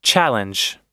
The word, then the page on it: challenge: American English